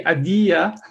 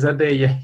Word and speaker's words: Adeia: Americans tend to say Adeia, but it's Adeia.